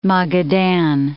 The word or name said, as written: Magadan